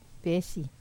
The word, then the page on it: pesi: wamesa